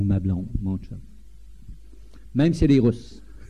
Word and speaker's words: chum: Et tous les hommes sont des chums. Ça s'écrit C-H-U-M, mais ça se prononce comme s'il y avait un T au début, un chum.